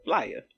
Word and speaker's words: Flyaa: Let me tell you, I ain't know it was Flyer until I heard the album. I've seen that boy post that thing a million times, because it's spelled F-L-Y-A-A. – F-L-Y-A-A.